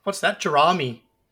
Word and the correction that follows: Jerami: I believe it's pronounced Jeremy, but Jerami sounds like salami.